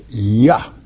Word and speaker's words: Yah: There are no J's in the Hebrew manuscripts, okay? The Hebrew alphabet, I should correctly say. Therefore, you cannot pronounce a J there. It's Yah.